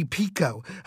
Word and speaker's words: Picoult: I mean, I always call you Jodi Picoult, and I hear some people call you Jodi Pico. I mean, what is the correct pronunciation? – It's actually Pico, but Stephen, you can call me whatever you want.